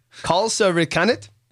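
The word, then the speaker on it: kalsarikännit: It's a Finnish word, and I believe it's pronounced kalsa rikanit.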